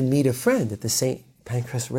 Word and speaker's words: Pancras: St. Pancras, I'm sure not pronouncing it in like the correct English way, but it sounds like pancreas, right?